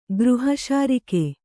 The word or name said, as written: ಗೃಹಶಾರಿಕೆ